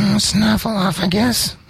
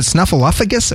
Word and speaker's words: Snuffleupagus: Snuffleupagus. – Mana. – Is it Snuffleupagus or Snuffleupagus? – No, you said it right the first time.